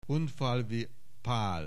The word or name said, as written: Hunfalvy Pal